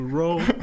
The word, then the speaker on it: ROAR: C'est pas sûr, ça s'écrit R-O-A-R, donc ça fait du Raw.